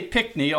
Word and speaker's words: Pinckney: Now, I say Pinckney, although there seems to be an extra N in the name, but that's how the locals I met pronounce it.